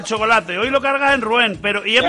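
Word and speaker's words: Rouen: En Rual. – En ¿cómo se llama? Ruén. ¿Y eso está? – Ruén. Sí, ay, lo pronuncia bien.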